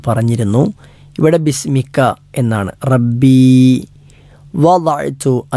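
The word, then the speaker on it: വദഅ്തു (وضعت): വദഅ്തു, വദ എന്നത് ദ നാവ്, ഇടതുഭാഗം ഇടതു പല്ലിലോ അല്ലെങ്കിൽ വലതുഭാഗം വലതു പല്ലുകളിലോ സ്പർശിച്ചു കൊണ്ട് ഉച്ചരിക്കേണ്ട അക്ഷരമാണ് ദ എന്നത്. ലാ എന്ന് നാവ് മുൻപജിലേക്ക് ചേർത്തുകൊണ്ട് ഉച്ചരിച്ചാൽ തെറ്റാണ്.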